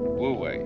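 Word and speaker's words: Wu Wei (無為): And then this is pronounced. This one is Wu, this one is Wei, Wu Wei.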